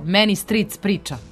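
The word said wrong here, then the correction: Manic Street Preachers: Jel znaš ko je? – Many Street Preachers.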